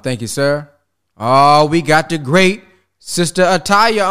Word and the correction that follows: Atiyah: on. Am I saying that right? We got to turn to unmute. – Sister Atiyah. – Atiyah, there she go.